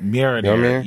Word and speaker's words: Mjolnir: I can never pronounce the hammer's name right. Can y'all pronounce it? – It's like Mjolnir? – Mjolnir. Yeah, Mjolnir.